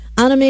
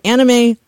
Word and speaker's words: anime: Most in like the Western fandom are probably going to say anime, but the correct pronunciation based on how Japanese syllabary works, it's anime. No one really says that really in the West. You know, it's kind of been sort of adopted just as anime, so no one's going to like make fun of you for saying anime instead of anime.